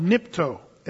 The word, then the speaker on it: nipto: So almost all the uses of the word wash that you see in the story, it's a Greek word called nipto. And if you want to spell it phonetically, N-I-P-T-O, nipto.